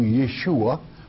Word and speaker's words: Yeshua: Joshua in the Hebrew tongue, Yeshua, properly pronounced, and meaning Yahweh's Savior.